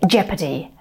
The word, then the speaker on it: jeopardy: Да, похоже на гепард, но это не гепард. Гепард будет cheetah. Ну, вот такой вот читер в английском. А это слово читается как jeopardy.